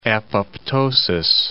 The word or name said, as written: apoptosis